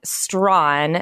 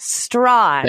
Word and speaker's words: Strachan: Well, it's hard because his last name is Strachn, but it's actually pronounced Strawn.